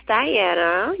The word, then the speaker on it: Dahiana: It's Dahiana.